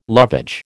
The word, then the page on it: lovage: American English